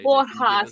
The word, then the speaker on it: Borja: Borja, so the J almost becomes an H.